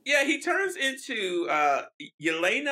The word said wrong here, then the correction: Ileana: I think how to pronounce it. Oh, no, Ileana. So, Ileana. Ileana.